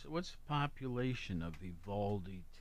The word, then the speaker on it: Uvalde: How do you say it? Uvalde, Texas? – Mm-hmm. Yeah. Pretty sure that's it.